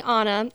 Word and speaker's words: Anna: So my name is actually Anna. It's spelled the same way as Anna Kendrick's. – Yeah. But she says it wrong.